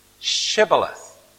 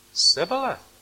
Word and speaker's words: Shibboleth: If he said no, they would say to him, then say, Shibboleth. And he would say, Sibboleth. For he couldn't pronounce it right.